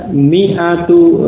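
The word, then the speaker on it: mi'atu (مِائَة): Ya, tulisannya walaupun ada alif, tetapi alif yang sesudah mim ini tidak dibaca, tidak diucapkan. Sehingga bacanya cukup mi'atu. Ya, mi'atu pendek ya. Ini bukan ma'atu, juga bukan mi'atu. Karena alif itu tidak dibaca, ya, tertulis tetapi tidak dibaca. Jadi mi'atu. Mi'atu rajulin, 100 orang laki-laki.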